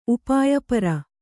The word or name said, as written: ಉಪಾಯಪರ